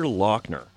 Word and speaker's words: Laughner: Who is Peter Lochner? That is spelled L-A-U-G-H-N-E-R.